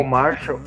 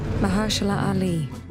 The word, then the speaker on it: Mahershala Ali: Não é o Marshall. – Mar, Mar, é. Eu não sei pronunciar, assim, Mahershala. Mahershala Ali.